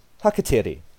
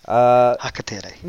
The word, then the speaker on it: hakateri: The word that we have picked out today for you is hakateri. – Hakateri. – Now, if you will, can you please pronounce hakateri for us? – Hakateri. – Very good. Kapai. Kapai. Okay.